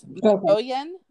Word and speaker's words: Mogollon: But if you say here in Arizona, they say things like Spanish-speaking people would. So it's Mogollon, I believe, is how you say it.